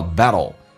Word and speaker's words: battle: T 跟 L 在美式发音当中去上一发 D 跟 L 叫 battle。 Alright，这句话我们再来一遍。